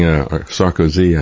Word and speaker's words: Sarkozy: We're going to take a look at what happened with one of their former leaders, Nicolas Sarkozy, is now facing Sarkozy, I think is the way you pronounce that. Emphasis is on the wrong syllable when I said it.